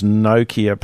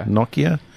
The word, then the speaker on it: Nokia: 8 years and we still can't pronounce Nokia properly. – Nokia.